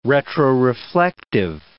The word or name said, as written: retroreflective